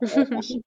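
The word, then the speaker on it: AUKUS: Ну, в России интересно, что его принято называть укусом, потому что по-английски он произносится как укус.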